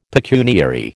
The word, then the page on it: pecuniary: American English